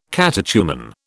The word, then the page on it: catechumen: British English